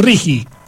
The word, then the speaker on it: RIGI: Pero aparte acá no tiene una U en el medio. claro, sino que es R-I-G-I, Rigi.